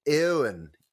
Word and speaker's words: Eoin: How would you say that first name? E-O-I-N, Eoin.